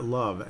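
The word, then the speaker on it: Loov: Victor Love, it's pronounced Love, but it's L-O-O-V.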